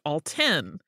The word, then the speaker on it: Alton: In the Mattoon Gasser episode, you mentioned the Alton Railroad, pronouncing Alton, as it looks like how it should be pronounced, like the Food Network host Alton Brown. As this is my hometown, I feel compelled to let you know that it is actually pronounced Alton.